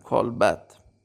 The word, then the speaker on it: کالبد: این رو به غلط با زمه بعضی وقتا تلفظ میکنن کالبد نداریم کالبد.